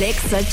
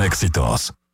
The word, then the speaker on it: Exa: Únete al Exa Challenge. – Escúchanos todo el día. En todas partes. Ponte. XAFM 89.9. No se dicen éxitos. Se dice EXA.